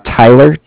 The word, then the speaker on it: Tyler: My name is Tyler, T-Y-L-E-R. I'm with Bank of Cardiff. It's spelled C-A-R-D-I-F-F, as in Frank Frank.